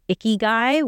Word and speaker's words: Ikigai: There's a Japanese philosophy called Ikigai, which sounds really weird to anyone who speaks English. Mm-hmm. It sounds like a dirty person, but it's spelled Ikigai.